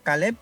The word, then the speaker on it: Caleb: Que sería Caleb, igual por YouTube y por todas las plataformas de streaming me puede conseguir por Caleb de esa manera.